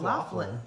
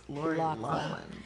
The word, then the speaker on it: Loughlin: It's not Loughlin. It's spelled Loughlin, but it's Lori Loughlin. – It's kind of low.